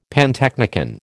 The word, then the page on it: pantechnicon: American English